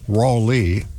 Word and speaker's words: Raleigh: Hey, at WPTF back in the day when they'd hire a new announcer, first thing they addressed was the pronunciation of what they call Raleigh, R-A-W hyphen L-E-E.